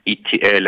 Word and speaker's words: Itiel: My name is uh, Dr. Itiel, and you pronounce Itiel like the letters ETL, you know, the movie ET, so it's an L.